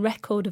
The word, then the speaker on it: record: And record is the verb, so the stress is on the second syllable.